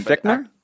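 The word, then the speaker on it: Fichtner: Fickner.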